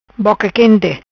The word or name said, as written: Boca Quente